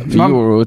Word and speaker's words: euro: Man borde ju uttala det som man uttalar Europa. Euro. – Euro, ja. – Euro. Alltså, man säger inte Europa eller Europa.